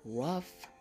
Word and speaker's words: rough: Now, in these words, the H is pronounced like an F, and you probably have heard these words before. We have rough and we have laugh.